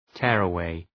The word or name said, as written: tearaway